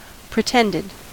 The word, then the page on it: pretended: American English